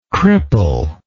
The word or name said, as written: crippler